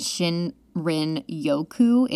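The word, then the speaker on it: shinrin-yoku: And Shinrin Yoku is actually how you say it in Japanese. So Shinrin means forest and Yoku means bathe.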